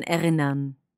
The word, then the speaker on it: erinnern: So it's best if you don't really pronounce the R at the end. You just make a sound. Mm. So erinnern.